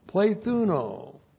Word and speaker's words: plethuno: And then we have the multiplication verb, plethuno. Plethuno, the multiplication verb, P-L, long E-T-H-U-N, long O, plethuno.